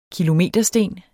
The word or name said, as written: kilometersten